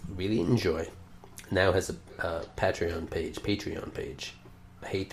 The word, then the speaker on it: Patreon: I was just been calling it Patron. But I guess it is Patreon. – It's Patreon, and you become a patron by using Patreon.